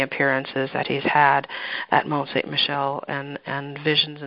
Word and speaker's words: Mont St. Michel: It became known as the Mount of St. Michael in French, which is pronounced Mont St. Michel, and this Mount of St. Michael today is a UNESCO World Heritage Site, is visited by millions.